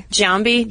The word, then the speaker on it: Giambi: Let's see, Jason Giambi. Probably saying that wrong. – Giambi. – Jason, yeah. Giambi.